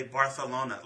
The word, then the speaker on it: Barcelona: We say it here, Barcelona. – Yeah. – I've never heard anybody say it that way. But the correct way to say it, people over in Spain say Barcelona, like B-A-R-T-H.